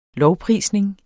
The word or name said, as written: lovprisning